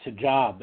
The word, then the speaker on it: hijab: Well, I was just going to let Charles know, I believe it's pronounced hijab is the headdress.